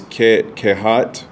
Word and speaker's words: Kohath: These were the names of Levi's sons, Gershon, Kohath, and Merari, which Kohath, by the way, is Kehat, sometimes pronounced.